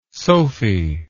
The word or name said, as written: sophy